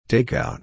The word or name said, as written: takeout